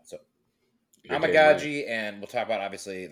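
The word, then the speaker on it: Amegadjie: I believe it's Omega G. Does that match up with what you? – Omagaji. – Okay. Omagaji. See, I'm so bad at pronouncing everything. Okay. Omagaji. I like it. I can do that. Omagaji. Like, oh my God, G.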